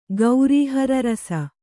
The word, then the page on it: ಗೌರೀಹರರಸ: Kannada